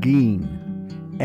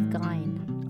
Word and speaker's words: Gein: Now, I have been mispronouncing his name, so I must come clean immediately. His name is Ed Gein. Edward Theodore Gein, although it's spelt G-E-I-N. – We thought it was Gein. – I did. – But it's Gein.